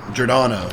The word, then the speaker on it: Giordano: Giordano, yep.